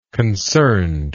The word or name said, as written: concerned